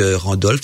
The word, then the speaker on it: Randolph: L'auteur c'est P.B. Radolf, donc Randolph, ça s'écrit R-A-N-D-O-L-P-H.